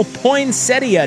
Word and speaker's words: poinsettia: Today, National Poinsettia Day. – Yeah, it fits. Love a good poinsettia. – And ChatGPT, I looked it up. ChatGPT says both pronunciations are acceptable. Poinsettia and poinsettia.